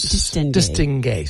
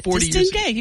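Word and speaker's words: Distingué: Distingue. – Sorry. I couldn't pronounce it when I bought it. – You got it. Distingue.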